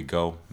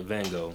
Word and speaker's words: Gogh: Um, but the, the project is called Here We Gogh. Go is spelled G O G H. Uh, I recorded it all in uh, Amsterdam earlier this year. So, hold on. Geo, okay, like like Van Gogh.